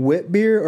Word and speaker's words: witbier: Okay, I gotta ask, are you saying wit beer or wheat beer? – Wit beers and wheat beers are the same thing. – W-I-T-B-I-E-R? Okay, because I keep thinking you're mispronouncing wheat.